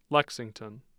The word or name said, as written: Lexington